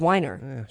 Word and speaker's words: Weiner: Oh, you mean Matthew Weiner? – Yeah, Matthew Weiner. – You mispronounced, but... – No, it's Weiner.